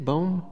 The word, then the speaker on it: BOEM: So when BOEM does, BOEM, is that how you say it? BOEM? – Yes.